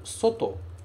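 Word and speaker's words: 外: Este 漢字 ele é pronunciado soto. Soto.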